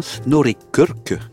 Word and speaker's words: Snorri Kirk: Alors, Beat, c'est le nom du nouvel opus du batteur norvégien Snorri Kirk, que je prononce magnifiquement. – À la norvégienne.